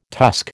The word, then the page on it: tusk: American English